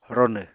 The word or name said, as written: ronw